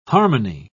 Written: harmony

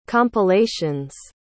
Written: compilations

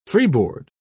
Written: freeboard

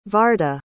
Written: varda

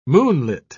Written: moonlit

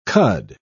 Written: cud

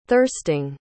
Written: thirsting

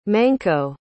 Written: Manco

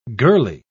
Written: girlie

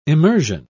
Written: emersion

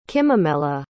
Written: kimimela

